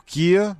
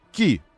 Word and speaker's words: Kia: На капоте новый двухмерный логотип Kia. Вы видите сейчас этот логотип. В нашей транскрипции читается как Ki. Ki. Просто Ki.